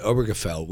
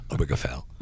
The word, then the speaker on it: Obergefell: No, what I'm wondering, you mentioned Obergefell, which, by the way, is a word I am amazed I just pronounced it properly. Obergefell.